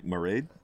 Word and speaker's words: Mairead: anyway, so I think it's Mairead, like Mairead, like almost like Maureen, but Mairead.